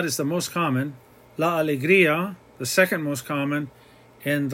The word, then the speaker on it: alegría: The word alegría, that IA, is two separate sounds. We put an accent on the I so that the I is heard equally as well as the A. La alegría, we're breaking up that diphthong.